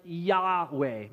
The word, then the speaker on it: Yahweh: And most scholars agree that we would pronounce that name something like Yahweh.